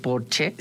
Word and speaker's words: Porsche: O decir que tengo un Porsche en lugar de decir tengo un Porsche, porque en alemán Porsche se dice Porsche porque todas las es al final se pronuncian como Marlene Dietrich, por ejemplo, que todo el mundo dice Marlene, pero es Marlene.